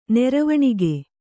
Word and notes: ನೆರವಣಿಗೆ: neravaṇige

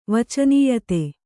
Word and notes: ವಚನೀಯತೆ: vacanīyate

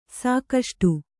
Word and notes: ಸಾಕಷ್ಟು: sākaṣṭu

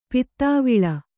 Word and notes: ಪುದುವಾಳ್: kannada

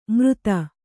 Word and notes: ಮೃತ: mřta

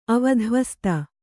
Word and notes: ಅವಧ್ವಸ್ತ: avadhyasta

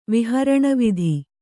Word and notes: ವಿಹರಣವಿಧಿ: kannada

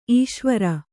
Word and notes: ಈಶ್ವರಿ: īśvari

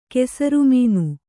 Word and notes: ಕೆಸರುಮೀನು: kesaru mīnu

